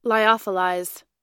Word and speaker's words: lyophilized: How do we say this word? – Lyophilized. – Lyophilized.